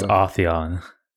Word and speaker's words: Atheon: Excuse me, it's Atheon.